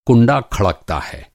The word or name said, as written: खड़कना